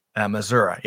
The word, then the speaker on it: Missouri: Missouri. Yeah, there's no I on the end of Missouri.